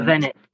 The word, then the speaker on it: Govanek: Talking beautiful, the sixth song, the halfway mark of the album, Govanek. Am I saying that right? – Govanek, yeah.